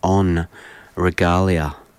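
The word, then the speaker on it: en Regalia: And I'll do the French pronunciation, on Regalia.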